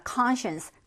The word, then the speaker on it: conscience: 자, conscience, conscience라는 단어입니다. 여러분들 conscience라고 읽지 않도록 조심하셨으면 좋겠습니다. 이제 science는 과학이고 얘는 conscience예요.